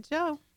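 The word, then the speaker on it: Jo: So Tracy Joe.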